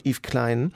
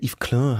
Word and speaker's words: Yves Klein: Aber im Städelmuseum in Frankfurt hängt eins von seinen Bildern von Yves Klein, oder Yves Klein hat man ihn eigentlich ausgesprochen,